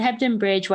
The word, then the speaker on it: Hebden Bridge: It's in Hebden Bridge, right? Did I say that right? – Yeah, it's in Hebden Bridge, West Yorkshire, in the UK.